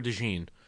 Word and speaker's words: DeJean: Mike Florio welcomes in Cooper DeJean, and he does not correct him. – Okay. Cooper DeJean. All right, I'll roll DeJean.